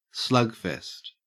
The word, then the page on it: slugfest: Australian English